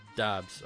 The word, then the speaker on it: Doubs: Is it Dobbs or Dubes? I can't. I don't know how to say this man's name. I've heard Dobbs everywhere. – Dobbs. Okay. Well, anyway, uh, I'm just swapping rookie rookie wide receivers here.